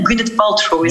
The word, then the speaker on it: Gwyneth Paltrow: Ehm, die, ja, die, dat, ik heb het nog juist uitgesproken, hè? Gwyneth Paltrow is het, toch? Ja, ja.